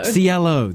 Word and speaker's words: Cielo: You've got a pretty big year lined up, which we'll talk about in a second, but this new track, Cello Drive, am I saying that right? Cello? – Cielo.